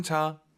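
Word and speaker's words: 茶: 이게 분명한 건 한글에 있는 그 차, 한국어의 차는 아니에요. 발음이 차 이거 아니에요. 약간 차 끈덕지게 차, 그죠? – 是的. – 너무 또 우리가 오버해서 촤 이건 아니에요. 차.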